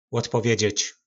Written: lodpovedzec

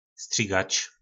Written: strigac

